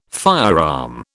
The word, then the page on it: firearm: British English